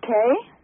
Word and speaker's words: Kay: The name is Kay. – Hi, Kay. How do you spell that? – K-A-Y. – K-A-Y.